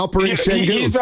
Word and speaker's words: Alperen Şengün: Yeah, Alperen Şengün.